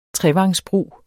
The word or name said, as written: trevangsbrug